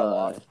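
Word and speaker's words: Locke: The Lock, I think that's how you say his name. – Yeah, Lock.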